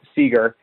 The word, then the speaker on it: Seager: I know they get Corey Siegler back. – Corey Siegler? – I know there's a lot. That's totally pronounced that wrong. Seager. I just threw the L.